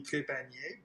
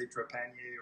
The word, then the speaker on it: Trepanier: Well, in French, it would be Trepanier, but in English, you know, you just say Trepanier or Trepanier, but, you know, I never quite know where to put the emphasis on the right syllables, kind of thing.